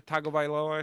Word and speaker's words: Tagovailoa: Yeah, Taulia Tagovailoa, I think is his name. – No, I did not. And that you did a very good pronunciation of his last name.